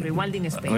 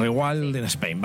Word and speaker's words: Rewilding Spain: Rewilding Spain. Bueno, no sé ni si lo he pronunciado bien. – Rewilding Spain. – Rewilding Spain, vale, perfecto.